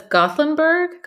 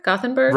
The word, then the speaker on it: Gothenburg: The way that I've been told, it's Gothenburg. Gothenburg. – Right, Gothenburg. Yeah.